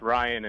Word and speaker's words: Ryan: It's Ryan and Brady, yeah.